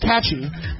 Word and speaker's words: Catgy: For $30, a British company called Catgy, pronounced Catchy,